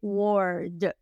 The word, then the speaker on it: word: Vamos a pronunciarla bien. Empezamos con la W. La W suena como U. U wo, wo, wo. Luego la R muy suavecita, Word Y luego la D.